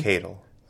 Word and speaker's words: Decadal: Decadal.